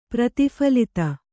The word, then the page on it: ಪ್ರತಿಫಲಿತ: prati phalita